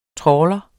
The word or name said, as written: Trawleren